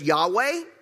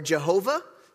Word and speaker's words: Yahweh: And sometimes that's pronounced Yahweh or Jehovah. Some of you guys have heard both of those.